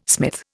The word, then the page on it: smith: Indian